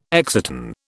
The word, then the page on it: exciton: British English